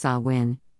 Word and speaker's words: Samhain: In modern times, Samhain, a Gaelic word pronounced Samhain,